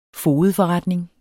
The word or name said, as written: fogedforretning